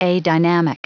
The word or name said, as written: adynamic